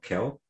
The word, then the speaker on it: Khill: Am I pronouncing it right? It is just K-H-I-L-L, Khill. – I think that counsel pronounced it Khill, but Khill? Okay. – The media seems pronouncing it as Khill.